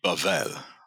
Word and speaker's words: Babel: So in Hebrew, it would be Bavel. – Bavel. – But the accent in Hebrew is always on the last syllable. – Right. – So it'll be Babel instead of Babel.